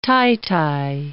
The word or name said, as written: titi